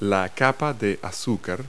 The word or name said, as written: icing